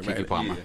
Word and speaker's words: Keke Palmer: I'm saying it right, Keke Palmer.